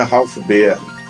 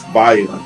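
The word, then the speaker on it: Ralph Baer: E eu falei errado, não é Ralph Beer, Ralph Bayer vamos ressuscitar os mortos, estamos eu daí.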